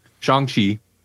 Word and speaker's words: Shang-Chi: we watched Shang-Chi. Thank you. I couldn't remember the correct pronunciation. Shang-Chi.